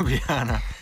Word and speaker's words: Ljubljana: And then Ljubljana. Did I say that right? Ljubljana? – Close enough. – Ljubljana.